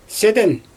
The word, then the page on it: sɪdɪn: Xyzyl